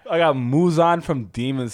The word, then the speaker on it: Muzan: Yeah, say it right. It's Muzan.